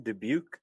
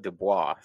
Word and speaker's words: Dubuque: I got to ask, has it always been Dubuque? I've always called it Dubois, so I've been saying it wrong the entire time.